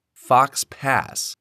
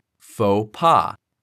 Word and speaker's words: faux pas: Based on the spelling, you might think that it sounds like fox pass, but that is not the case. Instead, the O in faux makes an O sound and the X is silent. In pas, the A sounds like the A in the word caught, while the S is silent. Therefore, the term is pronounced like faux pas.